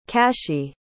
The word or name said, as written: cashi